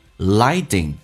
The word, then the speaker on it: lighting: 体验一下 continue lighting, 饱满一点 I, lighting, T 发 D 这个发音，两个元音中间所加的辅音要被浊化。